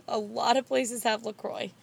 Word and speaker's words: LaCroix: Like they've is seen actually it, how you say it. La Croix.